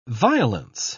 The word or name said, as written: violence